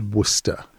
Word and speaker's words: Worcestershire: Worcester or Worcestershire is pronounced Worcester. I mean, people say, "Would you like some Worcestershire sauce?" And you go, "It's Worcester sauce." – Yeah, Worcestershire. – I mean, you just leave off the shear anyway.